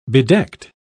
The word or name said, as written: bedecked